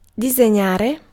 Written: style

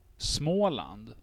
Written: Småland

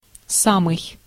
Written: même

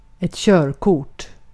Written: körkort

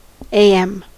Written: matin

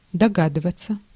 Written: deviner

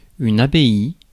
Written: abbey

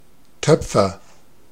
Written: potter

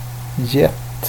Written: gett

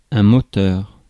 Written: motive

